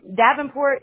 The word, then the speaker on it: Davenport: Hey, I'm Davenport is still my legal last name.